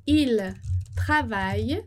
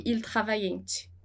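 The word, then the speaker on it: ils travaillent: E a gente não vai pronunciar o ENT. Então, o ENT é mudo, ele não vai ter som. Il travaille demain matin, il travaille. Nada de il travaillante, tá?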